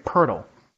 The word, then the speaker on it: Poeltl: I know there's a lot of different ways to pronounce his name. I've been hearing it all over the place, but I've been hearing it Poeltl.